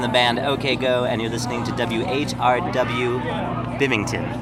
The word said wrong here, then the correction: Binghamton: Is that right? – Binghamton. – Bimington. How do you say it? – Binghamton. Yeah. – Oh, I spelled it wrong. How do you spell it? – B-I-N-G. – Oh. – H-A-M. – Oh. Why didn't you tell me? – Sorry, man. – Binghamton. – Yeah, Binghamton.